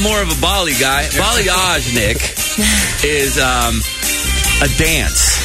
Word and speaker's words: balayage: I've looked it up on several YouTube videos, and a few hairdressers said balayage.